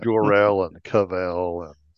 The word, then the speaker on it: kvell: It's K-V-E-L-L. I don't even know. Kvell? – Kvell? – K-V-E-L-L. – Kvell? Like Vell, only with a K on the front. Kvell.